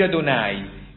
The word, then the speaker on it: Adonai: Ricordate il Signore Dio, anche questo è importante, ricordate il Signore Dio, dicevo l'altra volta, Signore è in ebraico è Adonai, si legge Adonai, ma in realtà è tetragramma.